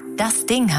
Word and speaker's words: DASDING: Wir wollten hier bei UFM nur kurz Bescheid sagen, dass wir am Montag ab 5 Uhr morgens das Ding heißen. Schreibt man übrigens ganz genau so, wie man es spricht. DASDING. Alles groß und zusammen.